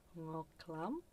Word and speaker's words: Ngoc Lam: And before that, you heard a Vietnamese track called Ngoc Lam. – Yes, your pronunciation is right.